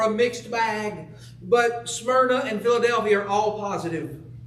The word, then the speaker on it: Smyrna: It's the church at Smyrna. Unless you're from more Warren County and it's Smyrna. Don't know why they pronounce whatever.